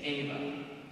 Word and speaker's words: Eva: So in Latin, the name of Eve is spelled E-V-A, it's Ava. And Mary, when she's greeted by the angel, is greeted with Ave, A-V-E.